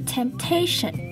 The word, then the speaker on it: temptation: 首先第一个要注意的是 temptation。 temptation 中的 T-I-O-N 发音为省，而字中的 A 则是为长音的 A 的音。